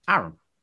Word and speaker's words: Aaron: Aaron.